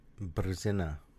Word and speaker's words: brzina: Brzina. – Bravo. Way to roll your R's there. Brzina.